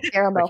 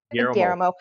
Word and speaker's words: Guillermo: Guillermo. – I can't say it. – Guillermo. – Guillermo Del Toro.